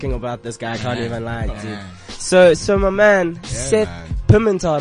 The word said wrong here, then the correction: Pimentel: It's actually Pimentel. – Pimentel. – Yeah, it's weird. It's Portuguese.